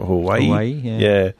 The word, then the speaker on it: Huawei: and the Chinese manufacturer Huawei, if that's how you say it, Huawei.